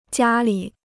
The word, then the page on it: 家里: jiā lǐ